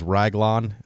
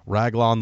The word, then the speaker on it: Raglan: And, in fact, the very beginning of the game has this, like, short story where someone yells, like, his name is Raglan, I think, is how you pronounce it, R-A-G-L-A-N.